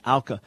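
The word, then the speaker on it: Auca: I've pronounced it Aka for years, but I guess it's Alka.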